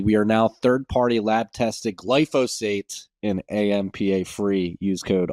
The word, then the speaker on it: glyphosate: I've been pronouncing glyphosate wrong for my entire life. There's some like middle of the word spoonism. I've been calling it glysophate for the many many years.